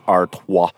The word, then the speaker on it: Artois: Well, people do use different pronunciations, but Stella is a Belgian, and, you know, they pronounce it Artois.